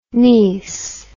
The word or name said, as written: nies